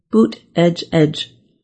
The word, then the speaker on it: Buttigieg: Transportation Secretary Pete Buttigieg, whose last name still causes some problems. During his 2020 presidential run, his campaign made shirts with the pronunciation Buttigieg to help.